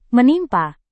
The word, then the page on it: menimpa: Indonesian